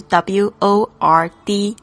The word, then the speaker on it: word: 那我们要特别注意一下这个 world，world 是很多中国学生发不好的声音，常常会跟另外一个字搞混，就是 w o r d 这个字。这个发音 word 会搞错，因为呢，这个 o r 这个声音呢，er 跟这个 l 的这个翘舌音呢搭配起来，其实是中文里面没有这个字的发音，所以呢会觉得比较困难一点。但是我们多多练习就可以掌握的，就是你的舌呢，舌头在发 er 的时候是卷起来的，但是你要发 l 的声音呢，记得翘起来，然后抵住这个前排牙齿的这个上排牙齿的后面，world。